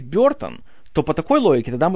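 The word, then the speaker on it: Burton: Объясню, почему я говорю Бертон, а не Бертон, как у нас принято его произносить. Вообще, оригинальное произношение фамилии звучит как Бертон. И вот этот звук в первом слоге, этот бр, это э получается, это нечто среднее между звуком э и звуком и.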